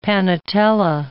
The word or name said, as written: panatela